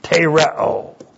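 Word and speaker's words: tereo (τηρέω): And then this great word, tereo. T, long E, R. E, long O, tereo.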